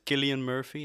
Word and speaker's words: Cillian Murphy: Ik ben meer de man van de geschiedenis en ik vind, moet ik het goed uitspreken, volgens mij is het Cillian Murphy en niet Cillian.